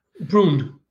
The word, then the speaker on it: Bruun: Bruun.